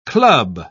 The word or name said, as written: club